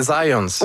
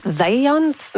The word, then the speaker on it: Zajonc: Non, c'est Zajonc. – Ouais? OK. – Ouais, j'ai-tu pas dit Zajonc? – Ben, j'avais dit plus Zajonc, mais. – Ah, ben, j'ai prononcé un petit peu à l'anglais. J'ai prononcé mon polonais à l'anglais.